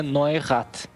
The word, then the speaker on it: Neurath: Dá para falar assim, o pessoal fala de tudo que é jeito, né? Mas o nome dele mesmo é Neurath.